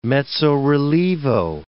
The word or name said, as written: mezzo-rilievo